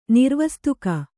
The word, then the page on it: ನಿರ್ವಸ್ತುಕ: nirvastuka